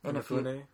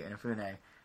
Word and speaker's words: Inafune: Inafune. – Thank you, Inafune.